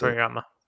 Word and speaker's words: Akira Toriyama: I always mispronounce Akira Toriyama.